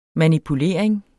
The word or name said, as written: manipulering